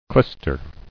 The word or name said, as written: Clyster